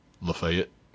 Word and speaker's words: Lafayette: I know it's spelled Lafayette, as in the famed General Lafayette, the Marquis Lafayette, but we say Lafayette.